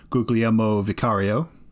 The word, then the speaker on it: Guglielmo Vicario: Yeah, they also got their replacement for Hugo Lloris, who they announced they were not going to re-sign this summer, in Guglielmo Vicario.